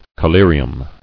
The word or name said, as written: Collyriums